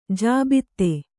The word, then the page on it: ಜಾಬಿತಾ: jābitā